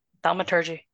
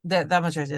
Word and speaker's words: thaumaturgy: The magicary, I'm sure I'm pronouncing that incorrectly. – Thaumaturgy. – Thaumaturgy, there we go.